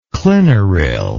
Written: clinoril

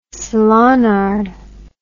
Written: salonnard

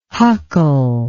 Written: huckle

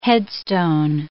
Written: headstone

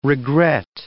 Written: regret

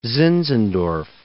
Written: Zinzendorf

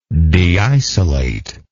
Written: deisolation

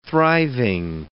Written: thriving

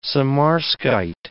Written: samarskite

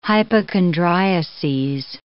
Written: hypochondriases

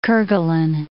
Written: Kerguelen

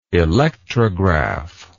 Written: electrograph

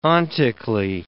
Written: ontically

